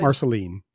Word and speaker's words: Marceline: And I've been wanting, it's Marceline. Is that the name of the place? – Marceline. Yeah.